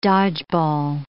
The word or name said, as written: dodgeball